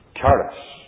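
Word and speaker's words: charis (χάρις): The Greek word for grace is charis.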